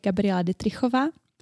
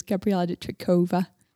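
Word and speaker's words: Gabriela Dittrichová: In Czech, I would say Gabriella Ditrikova. – Ditrikova. – But I also have an English version, which is Gabriella Ditrikova.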